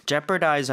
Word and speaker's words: jeopardize: So, jeopardize 하는 거 중간에 어휘는 발음을 하지 않습니다.